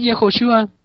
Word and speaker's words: Yehoshua: Yeshua es del hebreo y Yehoshua es del arameo. Así, Yehoshua. – Yehoshua.